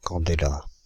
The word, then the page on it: candela: Paris; IPA: [kɑ̃.de.la]